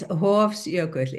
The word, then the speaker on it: Hofsjökull: Is it Hofjökull? – It's Hofsjökull, yes.